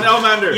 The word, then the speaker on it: Elmander: This rhymes with Salamander. – Johan Elmander.